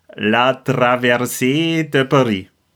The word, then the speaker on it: La Traversée de Paris: La Traversée de Paris.